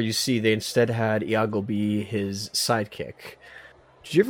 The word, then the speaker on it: Iago: Is it Iago? Is that how you say it? – It is Iago, indeed.